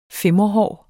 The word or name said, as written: fimrehår